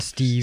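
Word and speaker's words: Steeve: Jeg tror, det udtales Steve. Steve. Ja, men, S-T-E-E-V, Mike.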